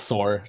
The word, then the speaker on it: Sor: Anyways, I'm with my guest, YaiSor, or is that how you say your name? – Just Sor. Just Sor is fine.